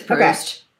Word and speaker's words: Proust: Gilbert says that she's not going to have any deep conversation about Proust. Is that how you pronounce it? Proust? – Proust? Yeah. – What is it? – I think it's Proust.